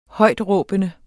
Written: højtråbende

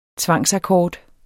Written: tvangsakkord

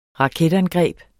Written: raketangreb

